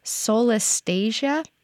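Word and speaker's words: solastalgia: Solestasia? Did I get that right? – You did.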